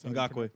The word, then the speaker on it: Ngakoue: 6, Yannick Ngakoue. – Yep. – I think if I said that correctly, I believe that's Ngakoue.